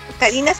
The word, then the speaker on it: Karina: O sea, Karina me gusta porque en Japón Karina se pronuncia Karina. No como en Estados Unidos, que es como Karina.